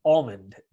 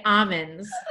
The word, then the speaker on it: almond: I say almonds. – There you go, folks. Long Island at its best. – How do you say it? – Almond. – Almond. – Almond. There's an L there.